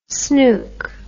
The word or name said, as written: snoek